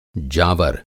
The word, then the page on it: जावर: Hindi